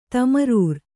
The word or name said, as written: ತಮರೂರ್